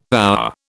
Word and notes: our: British English

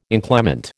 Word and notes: inclement: American English

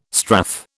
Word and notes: strath: British English

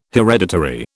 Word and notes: hereditary: British English

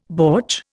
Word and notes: bortsch: Indian

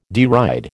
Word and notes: deride: American English

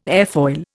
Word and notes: airfoil: Indian

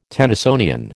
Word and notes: Tennysonian: American English

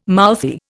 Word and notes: mouthy: Indian

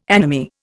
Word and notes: anomy: Indian

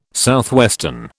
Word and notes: SW: British English

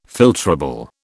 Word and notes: filtrable: British English

